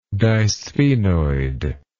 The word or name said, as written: disphenoid